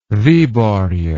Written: viborg

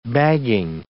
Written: bagging

